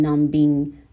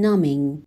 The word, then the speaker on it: numbing: فعل نام NUMB که بی توی این فعل سایلنت هست و تلفظ نمیشه به معنای بی‌حس کردن هست. And while all of that did succeed in numbing her، نمیگم numbing، میگم numbing،